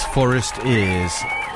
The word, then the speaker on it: Forestears: I think, actually, I mispronounced them and called them forced ears. I think it's forest ears. Or it could be forest ears. It's like forest ears, foresters, only with an A in it. For, F-O-R-E-S-T-E-A-R-S, if you want to get on Bandcamp.